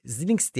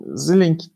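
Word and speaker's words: Xilinx: Ee, bu Zilinx diye mi okunuyor? Ee, Zil, Zilink. E, Zilink diye okuyabilirsin. Ya da Zilenx mi? ben de şimdi şey şu ana kadar bir tereddütüm yoktu. Şimdi kitlendim kaldım. Baştaki X Z olarak okunur.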